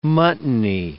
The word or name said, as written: muttony